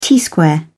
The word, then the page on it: t-square: British English